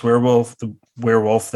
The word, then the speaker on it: Wehrwolf: I guess Wehrwolf, that's how you pronounce it, right? – Yep.